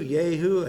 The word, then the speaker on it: Jehu: When the prophet Elisha was directed to anoint Jehu, J-E-H-U, Jehu, I think that's pronounced properly. Always reminds me of Yahoo, but it isn't Yahoo, it is Jehu.